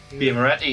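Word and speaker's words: Birra Moretti: Bia Moretti.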